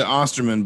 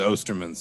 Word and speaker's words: Osterman: We pronounce it Osterman, but the German pronunciation is Osterman.